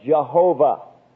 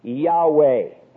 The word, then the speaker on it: Yahweh: Anytime you see the name for God in the King James Version in all capitals, it is speaking of Jehovah. For those of you who were with us yesterday, we said that this technically is an erroneous pronunciation of his name, because his name actually is Yahweh.